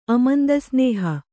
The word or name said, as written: ಅಮಂದಸ್ನೇಹ